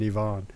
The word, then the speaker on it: Ivan: That was taken by Ivan Morozov, or Ivan, I prefer he calls it the French pronunciation, Ivan.